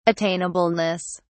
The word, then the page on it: attainableness: British English